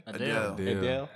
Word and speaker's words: Adele: He named one girl that, like, in the present form, like Adela. That's her name? – Adele.